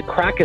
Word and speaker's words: craic: Like, so it's actually, the crack is spelled C-R-A-I-C.